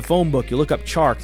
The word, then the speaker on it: Chark: Another DJ, not DJ Moore, DJ Clark. – It's actually pronounced DJ Chark. – That's in the outtakes. Clark. Chark. DJ Chark.